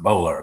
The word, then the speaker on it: Boulware: Bola. It's spelled Boulware, but it's pronounced Bola, apparently, who had been a drug smuggler.